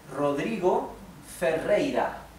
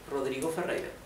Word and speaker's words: Rodrigo Ferreira: So this is Rodrigo Ferreira. Let me separate in syllables. Ro, dri, go. Fe, rei, ra. Okay, Rodrigo Ferreira.